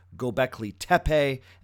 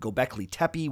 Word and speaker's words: Göbekli Tepe: I also spent a good portion of the episode explaining the significance of an archaeological site known as Göbekli Tepe. And yes, it is pronounced Göbekli Tepe and not Göbekli Tepe, which apparently I said a couple times.